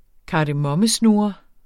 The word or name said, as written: kardemommesnurre